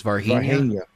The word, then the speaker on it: Varginha: And you know the pronunciation of the town, because I screw it up every time. It's Varginha.